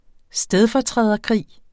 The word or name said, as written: stedfortræderkrig